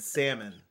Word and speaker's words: salmon: No, I say ask and salmon. L is silent.